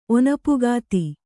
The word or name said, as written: ಒನಪುಗಾತಿ